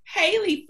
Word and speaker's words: Halie: I have Miss Andrea Fermin with Inspired by You Designs and Miss Halie Fermin with Not Your Scrunchie.